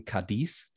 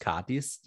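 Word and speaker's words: Cádiz: Äh, das wird tatsächlich so ausgesprochen, ganz ungewöhnlich. Äh, man würde eher dazu neigen, Cadiz zu sagen, aber es ist Cadiz, die Betonung.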